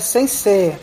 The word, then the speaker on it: sensei: E apenas para fazer um momento leitão aqui, que é importante, a pronúncia correta é sensei, que o E depois do I dobra o E, não cria o I. Então não é sensei, é sensei.